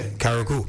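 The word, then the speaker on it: Carriacou: Karaku.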